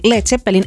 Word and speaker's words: Led Zeppelin: Ja muide selle kuulsa bändi nimi algselt oli siis kirjutati Led Zeppelin EAD on seal, aga arvati, et ameeriklased hakkavad seda hääldama lead. Otsustati, et olgu, et teeme siis selle nimekuju ka kergemaks, et siis loevad kõik vähemalt õigesti.